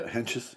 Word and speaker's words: Hentges: Yeah, Hentges.